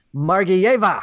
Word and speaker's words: Margiyeva: We welcome in Maria Margiyeva.